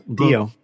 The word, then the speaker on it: Dio: One version features BB-8 and his new pal Dio. Am I saying that right? – Yep, Dio. Just think of the 80s heavy metal singer Dio, Ronnie James okay.